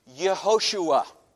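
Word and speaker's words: Yehoshua (יְהוֹשֻׁעַ): And especially for the kids, I want to mention that Joshua, pronounced in Hebrew, can talk about this at lunchtime with your folks, Yehoshua.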